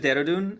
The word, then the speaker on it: Dehradun: The city's name is Dehradun, and the state is called Uttarakhand.